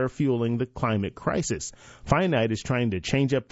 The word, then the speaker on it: Phinite: Finite, and this is spelled P-H-I-N-I-T-E.